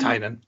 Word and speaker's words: Tynion: Tynion.